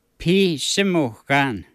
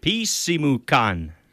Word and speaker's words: Pisimukan: In Michif, how do you say clock? – Bishimukan. – Bishimukan. – Pisimukan. – Pisimukan. – Yeah. – Pisimukan.